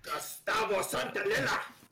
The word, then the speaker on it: Santaolalla: Gustavo Santaolalla!